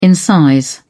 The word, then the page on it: incise: British English